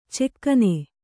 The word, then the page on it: ಚೆಕ್ಕನೆ: cekkane